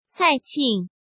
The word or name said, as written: Cai Qing